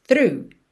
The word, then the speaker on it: through: Through my tree, through é só para acalmar quem se desespera quando vê essa palavra por escrito, T-H-R-O-U-G-H, a pronúncia dela é simples, through, through.